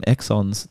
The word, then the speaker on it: exons: One more thing I want to mention is you called them exones. I've been calling them exons the whole time. Is it pronounced exones for the versus introns, or is it introns? – No, let me clarify there.